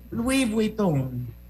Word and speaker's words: Louis Vuitton: Louis, Louis Vuitton.